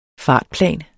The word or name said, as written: FARTPLANER